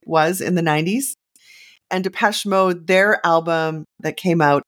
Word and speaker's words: Depeche Mode: Am I saying it right? Depeche Mode. – Right, yes. Depeche Mode.